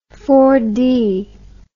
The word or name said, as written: four-cycle